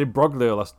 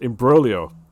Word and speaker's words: imbroglio: By the way, I said Imbroglio last episode, and it's pronounced Imbroglio.